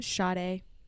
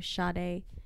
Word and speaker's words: Sade: Aaliyah Sade. Is that how you say her name? – Sade. – Oh, Sade.